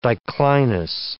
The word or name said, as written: diclinous